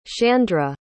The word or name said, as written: shandra